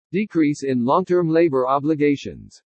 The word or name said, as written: DECREASE IN LONG-TERM LABOR OBLIGATIONS